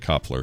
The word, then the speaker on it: Kopfler: This is from Chris Knopfler. Or Kopfler. I'm going to say Koppler because there's no silent N in there. – It's like Knopfler without the N.